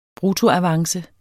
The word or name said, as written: bruttoavance